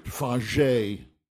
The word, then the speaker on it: Fage: And I added to that some Cheerios and a scoop of Greek yogurt, Fage. It's pronounced F-A-G-E, yogurt.